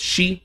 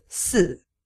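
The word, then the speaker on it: shi (四): And it's a lot easier to figure out why there's the fear of the number 4, because it's pronounced, in Japanese, it's pronounced shi, which is the same way that the word death is pronounced. And of course, in other languages as well. Right, right? – Yes. In Chinese, it is a... So death is si, and then the character 4 is si.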